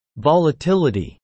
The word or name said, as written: volatility